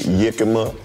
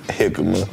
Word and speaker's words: jicama: This shit, some people call this shit yickama, and some people call it jicama.